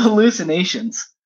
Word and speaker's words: hallucinations: Like you got to be really careful because uh, the hallucinate the excuse me, hallucinogen how do I say it? hallucinations? There we go. Take that out. Take that out. The hallucinations.